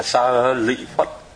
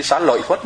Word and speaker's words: Sa Lị Phất: Sariputra chẳng hạn, Sariputra thì người ta đúng đọc là Sa Lị Phất, thì họ phiên âm sang quý vị nhớ không? Thì Sa Lị Phất là đúng, nhưng mà tại vì chữ lị chữ lợi nó viết một thứ, chữ lợi nó đọc là chữ lị luôn.